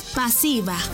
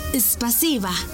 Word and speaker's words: спасибо: En ruso, gracias se dice спасибо. ¿Cómo? Спасибо. Una vez más. Спасибо. Y se escribe спасибо. S P A S I B A.